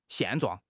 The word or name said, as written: 现状